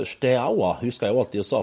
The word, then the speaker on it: Steaua: Jeg har alltid sagt bare 'Stoja men det er 'Steaua Bucuresti'.